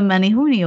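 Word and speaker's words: Menehune: You guys have the Menihuni over there. Did I say it right? – Yeah, absolutely.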